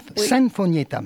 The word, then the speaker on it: Sinfonietta: Alors, on prononce Sinfonietta, c'est ça ? – Sinfonietta.